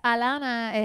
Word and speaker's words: Alana: Well, so it's Alana. It has an H, but it's a silent H because in Spanish you don't pronounce the H's.